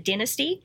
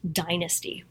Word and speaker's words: Dynasty: It's totally off topic, but when I was at a party with a bunch of former child stars and I said Dynasty, and they all laughed at me because apparently it's pronounced Dynasty.